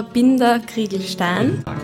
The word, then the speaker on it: Binder-Krieglstein: Grüße, Herr Dr. Binder Kriegelstein. Danke, dass Sie mich in Ihre Praxis eingeladen haben. Sie sind der Chefpsychologe vom Niederösterreichischen Roten Kreuz. Habe ich das jetzt richtig gesagt? – Ja, ganz genau.